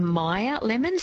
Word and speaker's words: Meyer: Al has texted through and she's asked a question, about myoria. She says, does the iron issue apply to myoria as well? – So maybe a Meyer lemon, so M-E-Y-E-R.